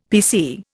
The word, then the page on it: PC: Indian